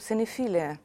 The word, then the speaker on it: cinephilia: Additionally, this problem of strange love for strange cinema is discussed with the application of the concept cinephilia. Am I right in pronouncing cinephilia? Cinephilia. Yeah.